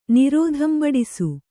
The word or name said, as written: ನಿರೋಧಂಬಡಿಸು